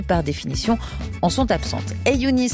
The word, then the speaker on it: 3YOONI: Son nom s'écrit 3-Y-O-O-N-I, mais ça se prononce Ayouni. Le premier son, c'est en fait une lettre arabe qui se dit Ay et qui ressemble à l'écrit au chiffre 3.